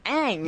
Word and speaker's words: Aang: And so, so the character of Aang is Aang. – Yes. – That's how they say it. That's what it is.